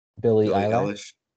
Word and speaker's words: Billie Eilish: Billie Eilish.